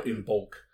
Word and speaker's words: Imbolc: It's spelled I-M-B-O-L-C or O-L-G. But when you say an Irish word, if it's got a G, if it's got a consonant at the end, you sound that letter. So it looks like Imbolc or Imbolc, but it's Imbolc.